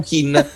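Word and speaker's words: Kean: C'è tutta una questione, perché allora in un'intervista di qualche settimana fa, di qualche mese fa, il padre ha detto no, si dice Ken. Poi lui, l'interessato, prima ha detto pronunciatelo come volete, e in un'ultima intervista in Inghilterra ha detto mi chiamo Kin.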